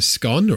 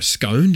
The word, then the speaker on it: scone: And you get a scone or a scone, depending on how you pronounce it.